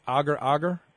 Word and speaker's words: agar-agar: I guess it's pronounced agar-agar. – Yeah, exactly.